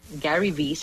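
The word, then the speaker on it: Gary Vee: Gary V se escribe G-A-R-Y y V se escribe V-E-E.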